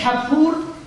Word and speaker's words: کپور: مرحوم همایی در حاشیه کتاب التفهیم میگوید تلفظ اصلیش کپور و با لفظ کفاره عربی یک ریشه دارد.